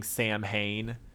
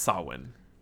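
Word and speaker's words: Samhain: It looks like you're saying Sam Hain, um, but it's Sawin or some some other iteration that's sort of close to that.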